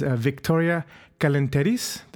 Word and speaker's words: Viktoria Kalenteris: My next guest is Viktoria Kalenteris. Did I say that right? – Wow, that's really nice. You even put a little Greek flair on it.